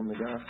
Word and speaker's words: Magos: Magos. I think the emphasis is on the last syllable, Magos.